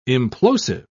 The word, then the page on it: implosive: British English